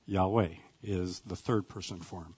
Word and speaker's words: Yahweh: And most scholars believe that that is pronounced as Yahweh.